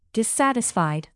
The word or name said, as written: dissatisfied